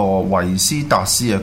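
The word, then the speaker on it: Vestas: 咁啊，佢系嚟自一个丹麦公司，就叫做 Ventus，发音冇错嘅话，就系呢个维斯达斯啊。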